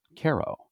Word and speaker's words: Cairo: Nebraska has a city that looks like Cairo, but they pronounce it Cairo.